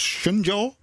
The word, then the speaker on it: Shenzhou: Ja, en ik heb het het eigenlijk spreek je het uit als Shenzhou.